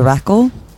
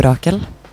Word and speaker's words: Rakel: So the name is composed of two parts. So it's Radio and Rackle, which is a women's name, obviously. I think most people, myself included, pronounce it in two different ways, depending on who we're talking to, I think. In Norwegian, I'd say Radio Rackle, Radio Rakel, just like in one word.